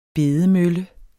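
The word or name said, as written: bedemølle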